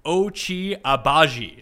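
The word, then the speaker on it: Ochai Agbaji: I think we're going to go with Kansas, as someone phonetically spelled Ochi Abaji. Is that correct, Ben? – That is correct, and Boom.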